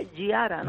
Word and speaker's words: Giara: A ver, la pronunciación correcta sería Giara, ¿no? – Giara. – Porque es un apellido de origen italiano.